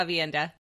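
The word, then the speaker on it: Aviendha: And who will no doubt correct my pronunciation because I only ever pronounced this name in my head, Avienda? – Yeah, Avienda.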